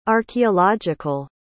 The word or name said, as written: archeological